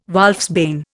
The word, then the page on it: wolfsbane: Indian